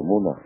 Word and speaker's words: 米纳: 到达米纳之后呢，呃，应该在这里做今天的铁身、这个的沙姆山，并且在米纳过夜，做第二天早上的这个反拔。那在这里呢，按照教法的律律呢，我们的礼拜呢应该按时去做，并且呢只礼只简短礼拜。米纳阿拉伯语呢还有一个发音呢叫做穆纳。